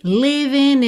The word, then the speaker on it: living: Well, how have you been, baby, living in sin? Aqui a gente até já tem um apóstrofo no lugar do G, indicando que aquele G final do ING não seria pronunciado, né? Living in sin.